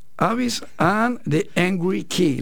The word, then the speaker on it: Avis and the Angry Kid: Avis and the Angry Kid, ¿lo pronunció bien? – Sí, es en inglés, porque bueno, la idea es que tocamos de todo un poco y música sin fronteras, decimos.